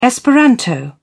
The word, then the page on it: esperanto: British English